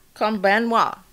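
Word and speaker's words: Konbanwa (こんばんは): Konbanwa. I'm practicing my Japanese. – All right. I love it. – So good evening. – So hey, what is it? – Konbanwa. – There you go. – Konbanwa.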